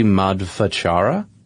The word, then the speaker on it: Madhvachara: Well, another one that I found was the Sarvamula Granthus, which is apparently attributed to be written by, and this is going to be a real tough one for me to pronounce, Sri Madhvachara. Sound about right? – That sounds good.